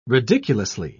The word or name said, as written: ridiculously